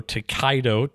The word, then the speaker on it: Tokaido: T-O-K-A-I-D-O, Takedo.